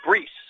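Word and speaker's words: Bruess: Now the batter will be Mason Brees, B-R-U-E-S-S. I've seen that name before. I know that it would be pronounced Brees.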